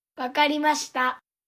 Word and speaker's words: わかりました: わかりました.